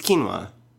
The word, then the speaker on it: quinoa: It's quinoa.